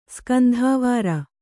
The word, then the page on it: ಸ್ಕಂಧಾವಾರ: skandhāvāra